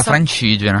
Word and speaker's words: Francigena: Noi andiamo a fare la via Francigena, spero di aver detto bene l'accento, in Toscana. – Sì, sì, scusate, è la via Francigena.